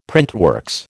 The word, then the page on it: printworks: American English